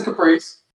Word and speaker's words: Caprice: is it a Caprice is how you actually pronounce it, or is it a Capri? – It's the Caprice.